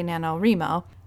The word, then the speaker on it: NaNoWriMo: As I speak, hundreds of thousands of writers are in the middle of National Novel Writing Month, or what is affectionately known as NaNoWriMo. Some people say NaNoWriMo. However you pronounce it, November is a month where writers have signed up with the goal of completing a novel draft of 50,000 words.